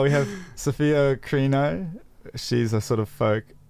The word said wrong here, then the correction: Chirino: She, did I say her name right? – It's Chirino, I think. – Chirino, yeah. Whoopsie daisy.